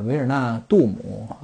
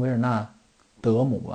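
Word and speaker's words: Wijnaldum: 呃，还挺麻烦的啊。就荷兰语来说，挺麻烦的，就是这个需要咬着点儿，需要拖着点下巴啊，不太贴合其他的一些主流语言，发 k h i f k h i f 要发 a 的音啊啊 k h i f 呃这么一个发音啊，所以它这个呃维尔纳杜姆啊，应该翻译成维尔纳德姆吧。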